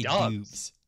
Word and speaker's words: Doubs: Yeah, but it's Romeo Doubs. Yeah, but it's D O U B S. – I want to say Dubs. Yeah. Listen, Nevada Pronunciation Guide 2019. I found it on the internet. Dubs. All right, fine.